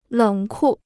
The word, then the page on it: 冷酷: lěng kù